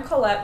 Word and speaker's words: Collette: I'm like, because I'm Colette with two L's, and Colette is usually spelled with one L. So I'm like, you know what? I'll go with Golden and two L's because I'm a Colette with two L's.